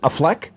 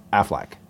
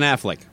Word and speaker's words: Affleck: Is a fleck, a flack, a fluke? Wait, how do you say it? I say Affleck. – Ben Affleck. – Yeah, all right. – Like the duck.